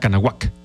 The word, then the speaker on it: Cuauhnáhuac: Nosotros decimos Cuauhnáhuac en castellano, Cuauhnáhuac, pero la pronunciación, si no mal recuerdo, que me dio Mariano en aquellos años era Canahuac. Algo muy parecido así, Canahuac.